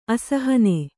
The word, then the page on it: ಅಸಹನ: asahana; Kannada